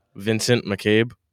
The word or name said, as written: McCabe